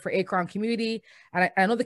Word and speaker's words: Akron: It's Akron.